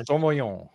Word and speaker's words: Sauvignon: Sauvignon.